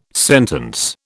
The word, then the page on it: sentence: British English